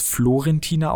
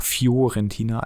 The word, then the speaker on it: Fiorentina: Wir hatten es gerade, es war jetzt Fiorentina dabei, wenn man, äh, spricht man es Florentina aus oder Fiorentina? Fiorentina eigentlich.